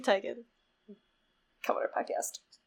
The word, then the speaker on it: Teigen: pronounced like Teigen.